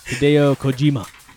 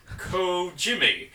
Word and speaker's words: Hideo Kojima: You want to say it for me? – Kojima. – I'm not even going to say it right. – Hideo Kojima. – There you go.